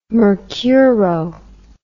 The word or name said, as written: mercuro